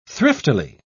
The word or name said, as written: thriftily